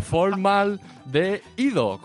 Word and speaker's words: formaldehído: Esto es la palabra, no me la he inventado, formaldehído, con acento en la I.